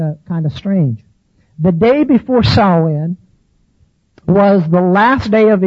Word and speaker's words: Samhain: it's pretty safe bet that it's pronounced Samhain. The first part rhyming with cow, Samhain.